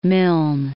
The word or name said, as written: Milne